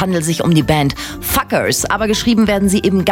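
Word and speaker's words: FCUKERS: The name of this rising electronic dance punk band is pronounced as Fuckers. Fuckers.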